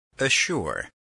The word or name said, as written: assure